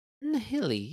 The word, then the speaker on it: Nihili: Nihili, yeah. Nihili? I never really thought about Nihili. It's like nihilism.